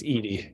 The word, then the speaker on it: Edie: And then he had these actors and other artists like Basquiat and Eddie Sedgwick. How do you say her name? Sedgwick? Anyway. – It's Edie. Edie Sedgwick.